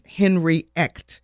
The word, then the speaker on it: Henry Echt: Drive train team lead, Henry Echt.